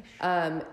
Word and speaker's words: Laois: So then there was this music festival called Electric Picnic back in Ireland in the countryside in Laois. I think that's how you say it. – Laois.